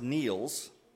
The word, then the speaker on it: Niels: Heidi Seaman's nephew, it looks like Niles, but it's pronounced Niels.